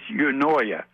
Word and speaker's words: eunoia: It's eunoia. E-U-N-O-I-A.